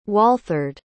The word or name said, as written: walthard